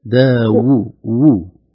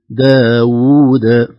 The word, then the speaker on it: داود: أنا سمعتها همزة، كأن الواو فقط همزة. صحيح نطقت همزة ولا ما نطقت؟ – بعيد. – داوود. – داوود.